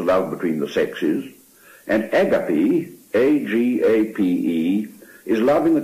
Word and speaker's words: agape: We are now to the fourth of these loves, which, as Lewis says, is agape, which we would pronounce agape.